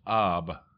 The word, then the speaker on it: Av: The fifth month is Av, which is pronounced Ab, and it is not mentioned by name in the Bible.